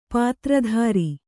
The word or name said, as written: ಪಾತ್ರಧರ